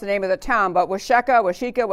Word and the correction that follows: Waukesha: whatever, Wisconsin. – I think it's Waukesha. – Waukesha. Okay, there's my happy Mr. Becker. Okay, Waukesha, we'll take that. I'll take that answer.